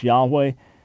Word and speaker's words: Yahweh: And then the Tetragrammaton introduced after that, most probably the pronunciation was Yahweh.